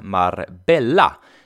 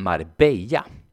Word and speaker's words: Marbella: Jag åkte dit för att lära mig spanska och något av det första jag fick lära mig var att stan jag befann mig i inte uttalas Marbella utan Marbella.